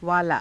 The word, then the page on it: vaalaa: Hindi